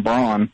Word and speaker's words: Braun: Oh, there's a dude that lived here, Wernher von Braun, or von Braun, they say, but I think it's pronounced Brown, but we all say Braun.